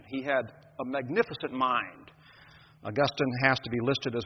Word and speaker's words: Augustine: It is not Augustine. My Latin professor in seminary was the late Gleason Archer. And if you ever said Augustine, he would reprimand you severely by telling you that Augustine is a town in Florida where the PGA Hall of Fame is. Augustine was a church father. He was a stickler about pronunciation. So it is Augustine.